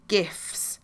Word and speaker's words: GIF: So in the next iOS release of WhatsApp, it's going to let people send GIFs.